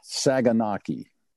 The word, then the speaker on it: saganaki: It is a cauliflower saganaki.